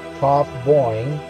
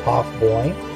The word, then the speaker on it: Taughboyne: Work was born with the surname Work, so it's spelled W-A-R-K. About 1792 in the Tauf Boyne, which is spelled T-A-U-G-H-B-O-Y-N-E, Tauf Boyne, or Tauf Boyne, County Donegal and Ireland.